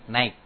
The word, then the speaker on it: Nike: Saya dapat faedah kemarin Syekh Ubay ditanya tentang merk Nike atau Nike. Bacanya apa? Nike gitu aja.